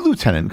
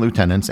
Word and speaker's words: lieutenant: A big question many people, including many British people, wonder is why Commonwealth countries pronounce it as lieutenant when there's nothing in the word that would indicate something that sounds like the letter F. The best reason I've heard is that in Old French, the word lieu was actually pronounced louf, and that was the pronunciation that took hold in Britain.